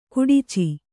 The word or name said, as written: ಖಡಚಿ